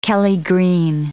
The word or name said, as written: kelly green